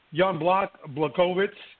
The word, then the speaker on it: Jan Błachowicz: I'm not going to call him Jan, because I called Jan Błachowicz. That's how you pronounce it.